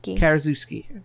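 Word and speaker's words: Karaszewski: Somebody please let this man know how to pronounce that. – Oh, I know. It sounds like I'm ignorant, but I can't. – Zinski. – Zinski, okay. – Karazuski. – That's what I said.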